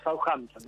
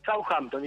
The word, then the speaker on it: Southampton: No, decilo en criollo Southampton. – ¿Puedes, me habilitas? – Southampton, estamos perfecto. Southampton.